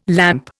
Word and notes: lamp: Indian

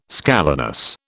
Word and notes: scalenus: British English

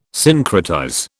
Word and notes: syncretize: British English

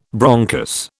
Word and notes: bronchus: British English

